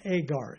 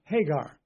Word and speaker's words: Hagar: For example, over in the book of Galatians, in the King James Bible, it speaks about Agar, A-G-A-R. That should be pronounced Hagar. Go back to the Old Testament. We're now talking about Hagar. The translators ignored what we call a rough breathing.